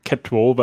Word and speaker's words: CATWOE: Spricht man die auch Catwow aus? Oder? – Catwow, ja. Also ich habe gerade erst letztens mit ein paar Engländern darüber gesprochen okay. – und die sagen Catwow, weil der, der ähm, ja, Finder, der das entwickelt hat, ist der Peter Jackland von der Universität in Lancaster.